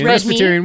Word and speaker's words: Presbyterian: Eat it's Presbyterian.